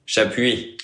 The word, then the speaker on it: Chapuis: Chapuis. – Chapuis. – Oui, puis. – Okay. You just pronounce the UI, but not the S.